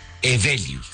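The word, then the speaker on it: Heweliusz: Sí, muchas, muy complicado porque es polaco, pero se pronuncia Evelius.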